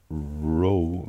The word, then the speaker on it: Roe: This was in the Ro Valley, Roy Valley, or Ro Valley. – How do you spell it? ROWE? – No, just Roe. – Oh, row like fish eggs.